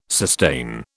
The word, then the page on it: sustain: British English